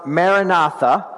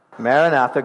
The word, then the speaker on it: Maranatha: So as I said, it's pronounced Maranatha. Can you say that? Maranatha, good job.